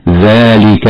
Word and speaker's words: ذلك: أما قوله تعالى: ذلك الكتاب لا ريب فيه هدى للمتقين، كلمة ذلك الذال تخرج مما بين اللسان وأطراف الثنايا العليا، ولا بد من إخراج طرف اللسان عند النطق بها هكذا: ذلك الكتاب، مع مد الألف التي بعدها مدا طبيعيا وصلا ووقفا بمقدار حركتين.